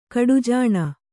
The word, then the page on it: ಕಡುಜಾಣೆ: kaḍujāṇe